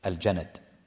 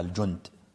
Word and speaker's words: الجند: فمعاذ كان في أكبرها في الجند، هكذا بالفتح الجند، وذلك يخطب بعض الناس ينطقها الجند،